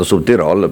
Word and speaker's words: Südtirol: Buongiorno, complimenti per come hai pronunciato Südtirol, perché insomma...